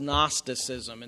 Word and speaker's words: Gnosticism: I think here he's talking about an early form of what's known as Gnosticism, and that's spelled with a silent G at the beginning, Gnostic.